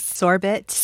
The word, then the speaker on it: sorbet: In fact, that story begins with me horrified by the way he pronounces sorbet. – He says sorbet. – Or something. – And then I found out later in fact checking that sorbet is a regionalism and a perfectly valid way of pronouncing it.